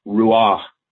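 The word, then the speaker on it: Ruach (רוח): In the Hebrew, it's Ruach. The English spelling would be R-U-A-H, Ruach. The meaning of the word, the pronunciation of the word is almost its meaning. It's an out-breathing. You have to say it with a lot of breath.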